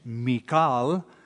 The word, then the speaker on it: Michal: So verse 11, Saul also sent messengers to David's house to watch him and to kill him in the morning, and Michal, or the Hebrew pronunciation, Michal, but I probably will just henceforth call her Michal because it's what we're used to.